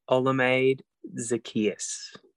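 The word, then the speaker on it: Olamide Zaccheaus: Olamide Zacchaeus.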